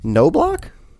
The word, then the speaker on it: Knobloch: It was directed by Kevin Knobloch? – Sure. – Sure. There's a silent K in his name.